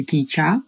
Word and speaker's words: Кича: Он называется «Кича. Ударение на последний» слог.